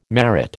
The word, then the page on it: merit: American English